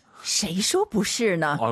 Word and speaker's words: 谁说不是呢: 谁说不是呢？ – 아유, 감사합니다.